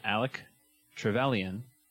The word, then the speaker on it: Alec Trevelyan: Our previous episode was The Winter Soldier versus Alec Trevelyan.